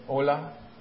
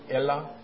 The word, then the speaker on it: Elah: How do you say that? In Ola? Ela? I was going to ask you that for a church, but didn't do that. Ola sounds like Spanish. Okay. Ela.